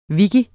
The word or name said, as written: wiki